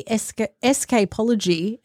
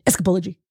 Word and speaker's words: Escapology: Well, I say Escapology, and then I asked Siri to play it today, and Escapology. – She said Escapology. Yeah. So, yeah, she schooled me again with my pronunciations.